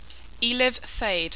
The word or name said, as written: Eliv Thade